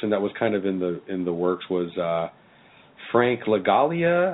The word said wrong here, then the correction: Langella: I'll write it down for you before you call his agent again. Langella.